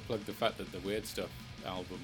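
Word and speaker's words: Wyrdstæf: Ian plays electronics and weird noises in a awesome paleolithic black metal band, Weird Stuff, which is spelt WYRDST weird AE thingy F.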